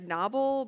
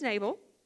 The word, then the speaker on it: Nabal: I think you pronounce it like Nobble, but I live in the South, so today he's Nabal.